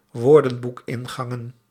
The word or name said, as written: woordenboekingangen